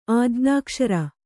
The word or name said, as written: ಆಜ್ಞಾಕ್ಷರ